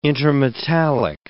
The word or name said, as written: intermetallic